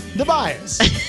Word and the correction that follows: DiBiase: He was the million-dollar man in the WWF. – Did I say it right? – No. – Oh, well, what is it? – Ted DiBiase.